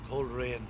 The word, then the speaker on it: Coleraine: Is it cool or is it always a coal sound? – Oh, no, I'm afraid we have got away from the Gaelic very much in this town. It's Coleraine.